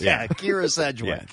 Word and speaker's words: Kyra: They pronounce it Kira, K-Y-R-A.